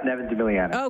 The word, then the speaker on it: Devin deMilliano: Yep, Devin deMilliano.